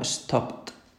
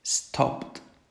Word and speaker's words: stopped: Por ejemplo, la palabra stop acaba en P. La P es un sonido sordo. Si hacéis la P, la garganta no vibra, ¿vale? Por lo tanto, la palabra escrita stopped se debe pronunciar como stopped. Stopped. Añadiéndole una T al último sonido que yo hago en infinitivo. Si el infinitivo es stop, le añado T detrás de esa P, stopped, ¿vale?